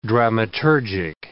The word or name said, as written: dramaturgic